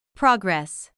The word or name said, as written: progress